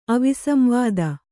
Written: ಅವಿಸಂವಾದ

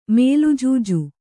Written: ಮೇಲುಜೂಜು

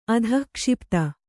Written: ಅಧಿಕ್ಷಿಪ್ತ

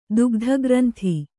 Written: ದುಗ್ಧಗ್ರಂಥಿ

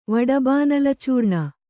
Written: ವಡಬಾನಲಚೂರ್ಣ

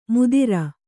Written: ಮುದಿರ

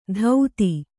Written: ಧೌತಿ

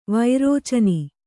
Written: ವಿರೇಚನೆ

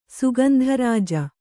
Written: ಸುಗಂಧರಾಜ

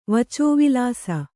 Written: ವಚೋವಿಲಾಸ

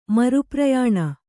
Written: ಮರುಪ್ರಯಾಣ

